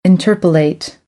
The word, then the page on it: interpolate: American English; ɝː